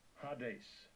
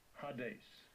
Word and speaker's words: Hades: Strong's G86, Hades, Hades.